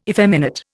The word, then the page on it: effeminate: Indian